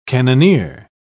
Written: cannoneer

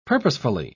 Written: purposefully

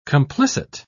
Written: complicit

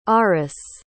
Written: aris

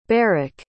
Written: barrick

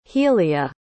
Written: healleah

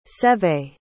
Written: Seve

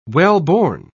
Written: wellborn

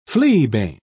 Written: fleabane

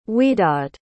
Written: widad

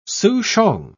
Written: souchong

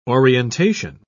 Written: orientation

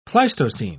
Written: pleistocene

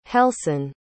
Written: helsin

